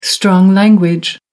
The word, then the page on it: strong language: American English